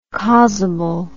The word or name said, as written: causable